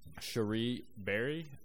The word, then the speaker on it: Cherie Berry: I'm going to go with Cherie Berry.